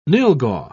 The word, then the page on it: nylghau: British English